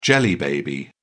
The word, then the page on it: jelly baby: British English